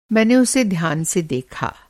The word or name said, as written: ध्यान